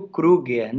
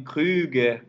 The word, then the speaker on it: Kruger: Eu assumi, no final das contas, eu assumi o Kruger, né? Porque, voltando a essa questão da música popular, se além de tudo eu exigisse do povo que ouve a música que pronunciasse o meu nome Kruger, como ele deve ser feito, eu preferi popularizar o meu nome.